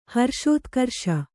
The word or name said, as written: ಹರ್ಷೋತ್ಕರ್ಷ